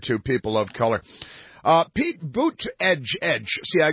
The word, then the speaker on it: Buttigieg: Apparently, the correct pronunciation for Pete Buttigieg's name is, in fact, Pete Buttigieg.